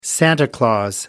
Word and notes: santa claus: American English

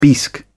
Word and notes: bisque: British English

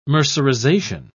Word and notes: mercerization: British English

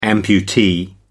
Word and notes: amputee: American English

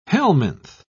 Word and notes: helminth: British English

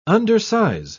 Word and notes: undersize: British English

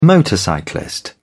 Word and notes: motorcyclist: British English

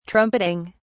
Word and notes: trumpeting: British English

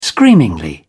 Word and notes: screamingly: British English

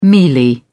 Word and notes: mealy: British English

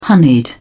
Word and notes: honeyed: British English

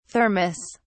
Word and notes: Thermos: British English

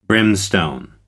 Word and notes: brimstone: American English

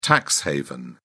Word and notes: tax haven: British English

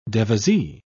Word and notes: devisee: British English

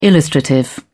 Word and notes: illustrative: British English

